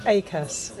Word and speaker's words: Akass: Akass.